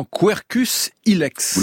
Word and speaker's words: Quercus ilex: Ouais, le chêne vert qui a pour nom savant Quercus ilex.